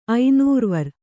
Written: ಐನೂರ್ವರ್

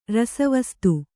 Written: ರಸವಸ್ತು

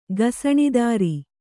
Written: ಗಸಣಿದಾರಿ

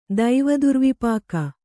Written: ದೈವದುರ್ವಿಪಾಕ

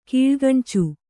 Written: ಕೀಳ್ಗಣ್ಚು

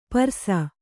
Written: ಪರ್ಸ

